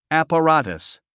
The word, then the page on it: apparatus: æ`pərǽtəs; アパラタス